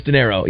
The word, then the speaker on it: De Niro: Yes, De Niro. Yeah, I went back and checked last night. – It's pronounced like money. Like De Niro.